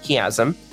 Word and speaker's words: Chiasm: He has produced two independent albums titled All Generations and Crooked Hill Chiasm. If you're looking for it online, it's pronounced the same way as like a chiastic structure of Old Testament works.